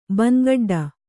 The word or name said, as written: ಬಂಗಡ್ಡ